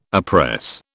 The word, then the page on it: oppress: British English